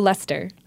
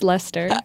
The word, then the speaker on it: Leicester: Leicester. It's just Leicester.